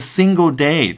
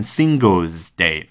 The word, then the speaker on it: single day: 单天记录这个 single day，大家千万别读成 singles day，那个呢是我们之前讲阿里巴巴双十一的那个 singles day。